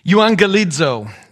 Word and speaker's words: euangelizo: Euangelizo. This is it in Greek. This is how you pronounce it in English, euangelizo.